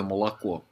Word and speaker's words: молоко: Как первая буква О в слове молоко. Молоко. Читается как «ы».